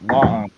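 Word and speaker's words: La'an: I mean, you had the Khan ancestor, Liana, whatever the hell her name is. – I think it's technically pronounced Long, but Lana.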